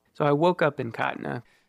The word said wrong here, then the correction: Katra: Every time we say Katna, we are mispronouncing the name of the town, which is actually Katra, K A T R A.